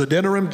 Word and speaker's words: Adoniram: I always get his first name wrong, but you pronounce it different ways, but that's how I've always pronounced it, as Adoniram Judson.